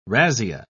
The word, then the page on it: razzia: British English